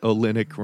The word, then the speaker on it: Olynyk: Olynyk because having sex with him. – It's not a coincidence that Olynyk rhymes with eunuch.